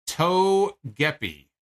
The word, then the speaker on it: Togepi: All right, so I'm being told it's pronounced Togepi.